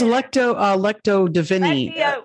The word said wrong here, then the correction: Lectio Divina: Lectio Divina.